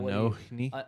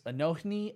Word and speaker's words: Anohni: Anhony. I don't know how to say it. Well, Anohni. – Yes, Anohni and the Johnsons. – There you go.